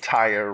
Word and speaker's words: Tyre: And he made Tyre rhyme with fire, so I have some modicum of support for that translation.